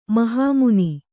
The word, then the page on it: ಮಹಮನೆ: mahamane